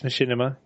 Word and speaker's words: Machinima: I thought about signing up for, I can never pronounce this, is it Machinima or Machinima? – Oh, yeah. No, I think it's Machinima, yeah.